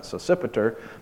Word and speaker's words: Sosipater: It says, Timothy, my fellow worker, and Lucius, and Jason, and Sosipater, that's how he's pronounced that, Sosipater, my countrymen greet you.